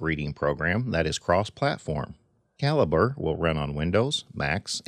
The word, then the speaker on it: Calibre: I am pronouncing it Calibre, as that is the way that Kovid Goyal, the creator of the program, pronounces it. I have heard several people call it Calibre, but I will honor the creator of the program and call it Calibre here.